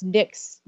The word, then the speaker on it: Nyk: Yeah, if you go to uh, my website, so it's Nyk's, Nick is spelled N Y K, and Danu is my last name, D A N U.